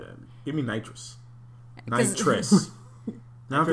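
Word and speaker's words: Knightress: And so for those listening at home, to try and distinguish Nitrous from Nitrous. It's spelled K-N-I-G-H-T-R-E-S.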